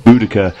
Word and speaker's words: Boudica: Of course, there are two pronunciations of her name, Boudicea, which is what I was taught in school, and Boudica, which has become more and more prevalent in recent years.